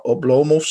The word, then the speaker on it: Oblomov: Uh, Oblomov, of ja, Oblomov denk ik dat je moet zeggen. Want in het Russisch moet je volgens mijn lerares Russisch meestal de klemtoon op de middelste of de tweede lettergreep zetten. Dus het zou Oblomov zijn.